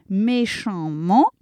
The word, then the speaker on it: méchamment: On devrait prononcer en hein, évidemment, mais il est très difficile de prononcer deux voyelles nasales à la suite. On dit donc amant, question de facilité. Donc méchamment, eh bien méchant, ANT, j'enlève le T, j'allonge le N, ça s'écrit A2M-ENT, méchamment, mais je prononce méchamment.